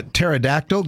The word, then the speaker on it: pterodactyl: You know why you can't hear a pterodactyl going to the bathroom? – Why is that? – Because the P is silent.